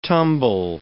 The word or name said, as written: tumble